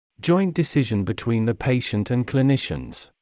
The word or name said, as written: joint decision between the patient and clinicians